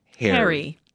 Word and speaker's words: HXRY: And Harry, with it, which is, if anyone's looking him up, HXRY. – Remember that, HXRY. – Which is pronounced Harry.